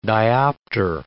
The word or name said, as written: diopter